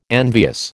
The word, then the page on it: envious: American English